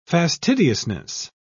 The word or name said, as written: fastidiousness